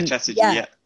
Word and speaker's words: Chatterjee: Dr. Rangan Chatterjee. I think I've said that correctly. – Yeah, Chatterjee.